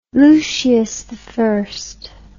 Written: Lucius I

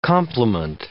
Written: compliment

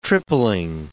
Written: tripling

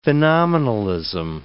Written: phenomenalism

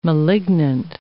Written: malignant

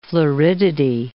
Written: floridity

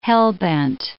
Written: hell-bent

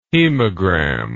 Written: hemogram

